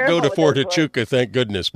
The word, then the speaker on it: Huachuca: There's an army base there, and I'll probably pronounce it wrong, but it's based Wichita or something like that. – No, Fort Huachuca.